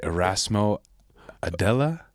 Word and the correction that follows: Erasmo Aldea: Erasmo, Erasmo Aldea. Aldea.